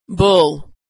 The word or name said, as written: bull